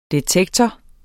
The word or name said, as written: detektor